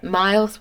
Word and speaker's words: Myles: Myles with a Y. – Okay. M-Y-L-E-S.